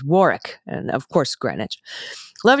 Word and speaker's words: Warwick: The second W in Warwick is not pronounced. Think Warwick.